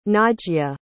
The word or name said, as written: najya